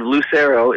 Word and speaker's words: Lucero: Tonight, I'm doing an entire set of Lucero, is how you pronounce it.